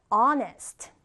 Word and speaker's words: honest: 자, 다음은 honest. honest. 자, 지금 쌤이 h 발음했나요? 네, h 발음을 하지 않았어요. 우리는 h를 무음 처리하고 그냥 honest라고 발음합니다. honest.